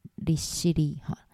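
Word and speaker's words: 利尻: 那立考的日文发音叫做立西利，西利其实就是爱奴语里面的岛的意思。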